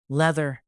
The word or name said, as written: leather